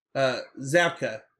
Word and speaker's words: Zabka: I think a huge part of that is Zappo and or it's not Zappo. What's I just said his name wrong.